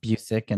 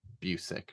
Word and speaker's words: Busiek: And then I eventually read something where he had written like what it was pronounced like Busiek. And his Twitter bio for a while said it's pronounced Busiek.